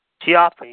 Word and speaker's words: Ciampa: Ciampa, you got it.